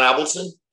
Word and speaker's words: Avildsen: John Avildsen.